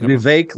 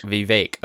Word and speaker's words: Vivek: Am I saying Vivek like Vivek? Cake. – Vivek.